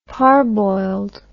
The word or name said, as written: parboiled